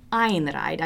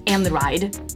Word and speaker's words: Einride: I always thought it was Einride. I thought it was E-I-N and I thought it was German, but it's actually pronounced Einride.